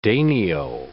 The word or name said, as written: danio